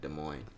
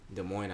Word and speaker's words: Des Moines: Des Moines. Des Moines, Iowa.